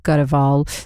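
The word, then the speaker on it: Grewal: Go to BBC Sounds and it is my name, Shay Cor Gurewal. The last name is spelt with a G-R-E-W-A-L.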